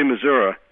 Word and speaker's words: Missouri: I say Missouri, which is to say I pronounce it correctly.